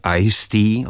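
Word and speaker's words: Eigsti: Ne, vystupovala dvakrát v pražském klubu Jazz Dog, poprvé jako host kvartetu pianisty a svého častého spolupracovníka jménem Taylor Ice-T, opravdu se čte tak jako ledový čaj, on se píše tak zvláštně, to teď nebudu rozvádět.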